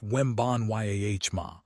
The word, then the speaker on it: Wembanyama: Even superstars like Victor Wembanyama, pronounced Wembanyama, are opting out of escalator clauses to help their teams keep depth players.